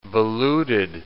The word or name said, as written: voluted